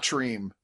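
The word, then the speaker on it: Tremé: Is that how you pronounce it? – Yeah, not Treme. – Not Treme, not no. Trem. – No. – Okay. Treme. – It is the Treme a la Treme of television.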